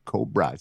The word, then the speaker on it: Cobrah: This is Mommy by Chris Lorenzo and Cobra, C-O-B-R-A-H. – All caps. – The H is silent.